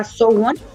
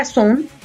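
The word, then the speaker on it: SONE: Su nombre fan club es Sowon, se pronuncia Sowon, yo pensé que era Sun y es Sowon,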